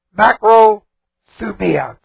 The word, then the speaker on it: makrothumia: Point one, love is the Greek word agape, A-G-A-P, long E. Patient. The Greek word is macrothumia, for patient. Macrothumia, M-A-K-R-O-T-H-U-M-I-A.